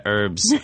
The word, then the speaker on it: herbs: Yeah, herbs, herbs. We say herbs. The、H is pretty silent there.